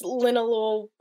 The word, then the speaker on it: linalool: Lin, linalool? Yeah, it's linalool, however you say it and it's an ingredient that apparently it also causes cancer, but it's often found in insecticide, like to kill bugs and whatnot.